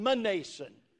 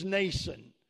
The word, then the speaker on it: Mnason: He said, the M is silent, and the man's name is Mnason.